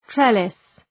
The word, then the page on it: trellis: ‘trelıs